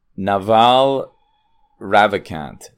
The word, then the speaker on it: Naval Ravikant: Today I'm reviewing podcast 1309, Naval Ravikant.